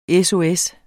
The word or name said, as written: SOS